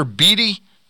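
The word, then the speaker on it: Beede: is it Tyler Beattie? Is that how you pronounce it? – Tyler Beattie, kid from Vanderbilt, the number one pick from Vandy.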